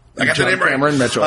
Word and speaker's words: John Cameron Mitchell: Did I get the name right? – John Cameron Mitchell, yes.